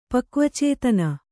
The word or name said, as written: ಪಕ್ವಚೇತನ